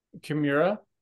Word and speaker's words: Chimera: Chimera? – Chimera, thank you.